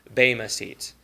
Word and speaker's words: bema: Now, as a little aside, you know, for however long I've been doing this, like for 18 years, I have mispronounced the Greek word for the judgment seat of Christ. I've said bema seat always, and I've just come recently to realize it's bema seat.